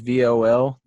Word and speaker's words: Voldemort: I've got a coach here, and his name is Voldemort. T is silent.